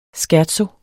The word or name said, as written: scherzo